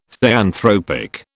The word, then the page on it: theanthropic: British English